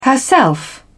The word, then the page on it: herself: British English